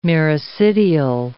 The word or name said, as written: miracidial